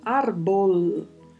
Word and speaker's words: árbol: Quanto à pronúncia, temos aí o vibrato do R, o som da letra L, que é gerado com a língua tocando o céu da boca na parte mais anterior, árbol.